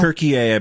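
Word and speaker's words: Türkiye: Turkey-A, I believe is how Turkey-A? They pronounce it in their country.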